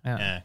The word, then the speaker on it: baklava: How do you guys pronounce baklava? – Baklava. – Baklava. – Yeah. – Baklava. – Like the accents on the last A. – Yeah, baklava.